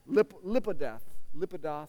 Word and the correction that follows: Lapidoth: something like that. Lapidoth.